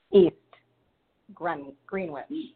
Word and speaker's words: East Greenwich: East or Eve's? – East Greenwich. That's how it's pronounced.